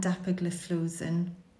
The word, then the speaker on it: dapagliflozin: So just to take care, the only SGLT2 inhibitor licensed in CKD currently are canagliflozin and dapagliflozin. – Great, thanks. Well done for pronouncing those drugs because they're not easy.